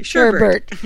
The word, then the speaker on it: sherbet: I learned how the correct spelling and pronunciation of the word sherbet. – Yeah, sherbet. – S-H-E-R-B-E-T. And I always thought it was S-A-G-R-B-E-R-T. – Sherbet. Yeah, it is sherbet.